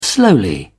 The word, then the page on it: slowly: British English